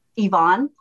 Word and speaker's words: Yvonne: Yvonne, my middle name is Yvonne. Y-V-O-N-N-E. And not too many people, you know, say your name, you know, with the E sound instead of the I sound.